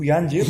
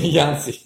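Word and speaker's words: Yancey: Bu Yancı. – Yansi.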